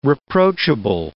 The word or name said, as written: reproachable